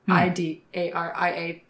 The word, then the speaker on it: Cnidaria: If anyone's interested, that's spelled Cnidaria because other languages. That did not originate in English.